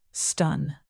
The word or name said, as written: stun